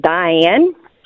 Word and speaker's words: Dian: Everyone, but I mean, everyone would always pronounce my first name wrong because it's Diane, but it's spelt differently. – How's it spelled? – It's it doesn't have an E on the end. It's just D-I-A-N.